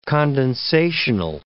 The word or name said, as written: condensational